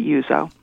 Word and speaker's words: Uzzo: All right, but it's spelled U-Z-Z-O. – That's right. It's really, I guess phonetically it would be Uzo, but we always pronounced it Uzo.